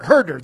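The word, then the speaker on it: Huerter: So, however, at Maryland, is it pronounced Huter? – Herder, that's it.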